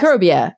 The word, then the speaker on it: Microbia: I want to make sure I'm pronouncing it right. It's Microbia, right? That's how you would say that word? Okay. – Yes. – Microbia, A Journey into the Unseen World Around You.